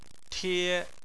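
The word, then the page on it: tie: tone 1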